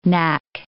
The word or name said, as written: knack